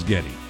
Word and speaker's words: Geddy: But she pronounced it Geddy. Geddy. Geddy.